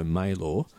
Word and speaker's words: Maelor: I mean, one of the things about the residency, and I should mention the name of the residency, which is Studio Maylor. It's spelled Stiwdio, which is the Welsh way of saying studio. – Yeah. – And Maylor is Maelor.